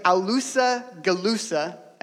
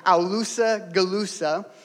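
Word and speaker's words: Aulus Gellius: For a contemporary of Jesus, written from the sec- second century, named Aulusa Galusa, that's a real pronunciation of his name, Aulusa Galusa,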